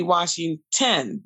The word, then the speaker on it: Washingtin: If they Google me, it's Tony Washington, WASHINGTIN.